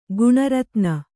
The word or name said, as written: ಗಣಾರಾಧನೆ